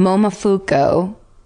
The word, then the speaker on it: Momofuku: Momofuku. Is it Fuku? – No, you said it right.